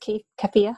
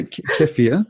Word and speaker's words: kefir: Do you find they usually can handle a little bit of kefir? I keep saying it wrong. – Yeah, kefir.